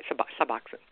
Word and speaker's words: Suboxone: Suboxone. Am I saying that right? Suboxone.